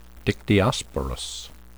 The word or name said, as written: dictyosporous